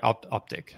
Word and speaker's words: Optiq: do I say this? It's Optiq, yeah. – I am saying that wrong. Right, Optiq. That makes total sense.